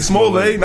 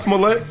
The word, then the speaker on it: Smollett: It's Smollett, not Smollett. – Same reason black people call Dr. W.B. – Du Bois. – He's Smollett now, bro.